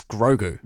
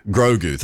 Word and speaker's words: Grogu: And how do I say it, Locke? The Mandalorian and Locke? The Mandalorian and Grogu? Is that how we say it? The new Star Wars movie? – It's Grogu. – Grogu, thank you.